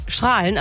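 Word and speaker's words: Straelen: Nicht wie bei Strahlen am Niederrhein, dass er mit A ausgesprochen wird.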